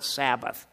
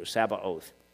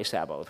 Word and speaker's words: Sabaoth: By the way, Sabaoth must not be confused with a somewhat similar word, Sabbath. They're totally different, so it's not Sabbath. We're talking about Sabaoth. Short A, oath, long O. Sabaoth is how you pronounce that in the Hebrew, Sabaoth.